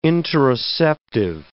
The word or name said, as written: interoceptive